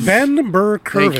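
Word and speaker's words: Burr-Kirven: Ben Burr-Kirvin.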